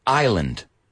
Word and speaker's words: island: Now, pronounce this word with me. Island. – Island. – Island. Not Iceland.